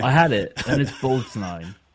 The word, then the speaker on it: Borgnine: Ernest Borgnine.